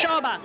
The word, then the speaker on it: Shoba: Shoba, S-H-O-B-A.